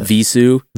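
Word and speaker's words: Vheissu: It's not even pronounced right. – How do you pronounce it? – Visu. – Visu? – Yeah.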